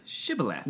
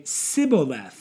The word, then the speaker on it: shibboleth: They couldn't pronounce the SH sound. – Yeah. – So they would say Sibboleth.